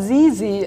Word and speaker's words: Sisi: Und ich würde gern mit Sissy anfangen oder wie wir gerade besprochen haben, Sissy ja, Sisi, äh, schreibt, Sisi.